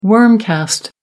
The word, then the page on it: worm cast: American English